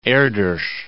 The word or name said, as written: Erdos